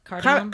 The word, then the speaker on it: cardamom: I will introduce a new caramel spice latte or a carmadone, or no, not carmadone. – How do you say that? Cardamom.